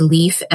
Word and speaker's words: Leaf: and it's Leaf, L-E-A-F, spelled like the leaf.